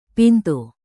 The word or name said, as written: pintu